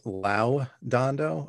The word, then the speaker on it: Lodondo: It's actually, it's pronounced Lodondo, I guess, because it's like Latin. It's Latin. So okay. Like when you think of when somebody graduates like summa cum laude, like Lodondo is like part of that verb or something.